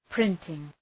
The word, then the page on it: printing: ‘prıntıŋ